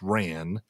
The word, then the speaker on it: wren: So when used in this context, wren is often pronounced ran.